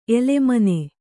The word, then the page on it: ಎಲೆಮನೆ: elemane